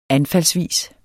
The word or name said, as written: anfaldsvis